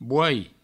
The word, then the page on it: boai: bouai